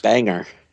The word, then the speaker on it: Bangor: It's pronounced news. Banger. – Banger? – I'm not even joking. That's how they say it.